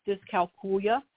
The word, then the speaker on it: dyscalculia: That's one of the pronunciations. The more common one is dyscalculia, as though you're saying Julia.